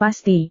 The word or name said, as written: pasti